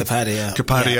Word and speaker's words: Asif Kapadia: I don't know how you pronounce the surname. – Asif Kapadia.